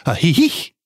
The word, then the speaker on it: Ajijic: And by the way, that's A-J-I-J-I-C. And the proper pronunciation is Ajijic.